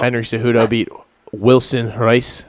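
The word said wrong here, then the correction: Reis: or hey, well, how do you say that? – Wilson Hayes. – Hayes, yeah. Very, yeah. These guys, man. Oh, there's an R there. Why is the R there?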